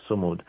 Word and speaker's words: Sumud: And for those who are listening to us, Samud is spelled S-U-M-U-D. Mm-hmm. S-U-M-U-D, Samud.